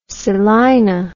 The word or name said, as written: Selina